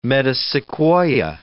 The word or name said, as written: metasequoia